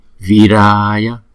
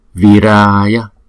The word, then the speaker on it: Viraya: Viraya, Viraya, Viraya. Chúng ta đọc rõ là chữ vi và chữ ra, bởi vì nó là hai nhịp, có chữ dấu gạch trên đầu là hai nhịp.